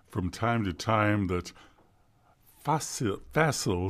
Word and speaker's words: facile: which is the sonata facile. Yeah, this has nothing to do with Christmas Story. Facile is how it's pronounced in Italian.